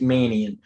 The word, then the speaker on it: Mannion: I pronounced it wrong. It's Manion. Yeah, Sean Manion.